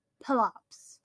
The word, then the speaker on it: Pelops: It was Pelops. – Pelops. – Like a plop, like a turd in a toilet. – Pelops.